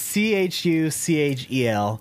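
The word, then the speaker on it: Chuchel: Is it like Churchill, but without a couple R's? – Yeah, it's Churchiel.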